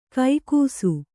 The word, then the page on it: ಕೈಗೂಸು: kannada; kaigūsu